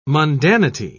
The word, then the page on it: mundanity: British English